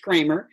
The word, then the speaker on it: Creamer: And for both, it's just my name, Lois, last name spelled Creamer, pronounced Kramer.